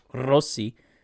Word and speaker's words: Rossi: He's like, well, technically you roll an R there. So it's Rossi.